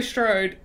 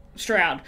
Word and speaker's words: Strode: It's weird that they say Strode, and I'm like, that's not how you say it in Australia, like the way she spells her last name. I was like, that's not how we'd say it here, because there's only one O, so.